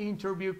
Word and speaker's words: interview: La palabra interview, como también pasa con internet, normalmente cuando va después de la N y una T, tiende a omitirse un poco en la pronunciación. Usted podría escuchar interview con la T, o también podrías escuchar fácilmente interview, interview, o internet, o internet. ¿Sí?